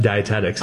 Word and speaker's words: dietetics: I got stuck on that dietetics. Is that how you say it? – Yep, dietetics.